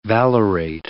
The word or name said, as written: valerate